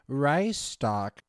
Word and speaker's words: Reichstag: I knew it started with an R, but thank you, Ivan, for letting me know who phonetically spelt it out for me. It's the Reichstag parliament building. The Reichstag.